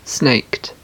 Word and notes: snaked: British English

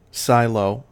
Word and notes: silo: American English

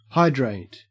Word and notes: hydrate: Australian English

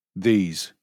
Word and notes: these: British English

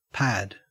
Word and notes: pad: Australian English

